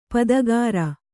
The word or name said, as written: ಪದಗಾರ